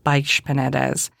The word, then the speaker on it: Baix Penedès: And then you have Baix Penedès, and that's spelled B-A-I-X. X in Spain is that sh sound.